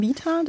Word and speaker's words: Vita: Ich glaube am besten Vita, das kann man besser aussprechen.